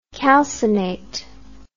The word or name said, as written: calcinate